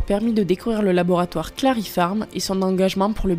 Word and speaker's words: Claripharm: Donc Clarifarm, c'est comme Clary, c'est comme pharmacie.